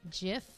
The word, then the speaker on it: GIF: And gif is how the man who originally created it pronounced it.